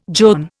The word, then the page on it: john: Indian